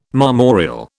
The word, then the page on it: marmoreal: British English